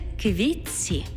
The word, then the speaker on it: квітці: У словах «квітці» та «тітці» звук «т» переходить у м'який подовжений звук «ц» – «квітці» – «тітці».